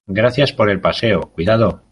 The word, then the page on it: cuidado: spaans